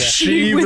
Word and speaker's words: Sheev: Sheev with a silent Y.